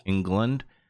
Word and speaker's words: Englund: Well, Vecna is played by Robert Englund. I think I said his name right, who plays Freddy Krueger. – Englund, who's Freddy Krueger in the Nightmare on Elm Street.